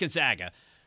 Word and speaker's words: Gonzaga: Gonzaga is how we used to pronounce it, but it's pronounced Gonzaga.